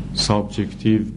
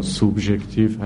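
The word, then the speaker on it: subjective: منظور از کسانی که میگن خیر و شر اخلاقی سابجکتیو به تلفظ انگلیسی یا سوبجکتیو هست همینه که تابع شخص قضاوت کننده هست.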